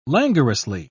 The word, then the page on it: languorously: British English